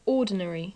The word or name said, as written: ordinary